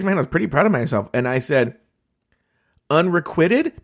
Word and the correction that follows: unrequited: And he was like, yeah, you just pronounced it wrong, but you got it right. – He went like, ugh, it's unrequited.